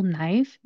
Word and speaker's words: knife: Now, if you look at the spelling of this word, it is spelled K-N-I-F-E. When we are pronouncing this word, what we're going to do is we're not going to pronounce the K.